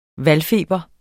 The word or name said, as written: valgfeber